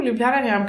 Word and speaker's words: Ljubljana Njam: It's probably one of those, you know, very local things, but I figured like, ooh, Ljubljana Njam kind of rhymes with banana njam.